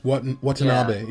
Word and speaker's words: Watanabe: Watanabe, yeah.